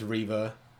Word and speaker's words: Reva: Is her name pronounced Reva or Reva in the show? I don't remember. – They say I heard Reva a lot. – Okay. – Pretty sure it's Reva.